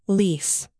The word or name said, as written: lease